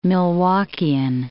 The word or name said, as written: Milwaukeean